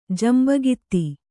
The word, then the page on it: ಜಂಬಗಿತ್ತಿ: Kannada